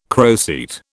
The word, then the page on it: croceate: British English